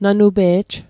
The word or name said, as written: nanupe'j